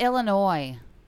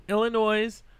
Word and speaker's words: Illinois: It's Illinois. – Illinois. – No S. S is silent.